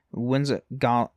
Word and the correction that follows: Wizengamot: Wizengamot. That's how you say it.